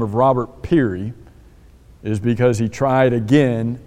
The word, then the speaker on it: Peary: Guy by the name of Robert Peary, I think is how you would say his name. It's spelled P-E-A-R-Y.